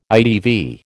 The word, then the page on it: ITV: American English